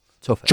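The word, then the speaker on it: chófer: Yeah, with the stress on the O. Oh, chauffeur. – Chauffeur.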